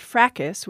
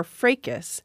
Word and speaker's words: fracas: And that word, we have a pronunciation question on it, which is it fracas, which is how I say it, or fracas? I checked several standard dictionaries. Many of them provide both those pronunciations, which made me feel better. They do put fracas first.